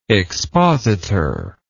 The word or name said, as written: expositorially